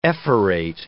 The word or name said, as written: ephorate